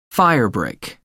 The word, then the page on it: firebrick: American English